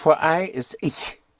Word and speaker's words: ich: And I want to make sure right from the beginning of how to pronounce a sound which we don't have in English, and that is the sound of the ch, ich. I is spelled ich, pronounced ich. It's a very soft sh sound. So instead of ich, make it ich, ich. That sound is more in the back of your mouth.